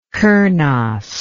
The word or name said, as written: kernos